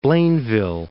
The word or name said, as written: Blainville